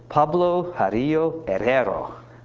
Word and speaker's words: Pablo Jarillo Herrero: Pablo Jarillo Herrero.